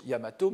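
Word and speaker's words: Yamato (大和): C'est-à-dire que normalement c'est Daiwa qui est écrit, euh, qui se prononce Yamato, mais on peut aussi le prononcer tout seul.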